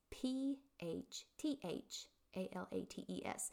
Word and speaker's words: phthalates: So phthalates, P H T H A L A T E S. Um, it's pronounced phthalates, just with the TH, but they are found in fragrance.